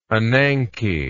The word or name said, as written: ananke